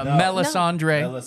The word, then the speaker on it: Melisandre: No, Melisandre.